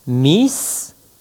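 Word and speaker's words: mi scias: Soit à dire par exemple miscias, je sais. Pour prononcer cette phrase convenablement, il faut associer le s de scias avec le mi qui précède et lire miscias.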